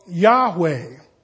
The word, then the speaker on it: Yahweh: Now, we know that a J for us is really a Y. It would be Yahovah. A V for us is really a W, but it's pronounced kind of like Volkswagen, you know. Not a Volkswagen, a Volkswagen in German. So Yahweh is probably the best guess of what this actually means.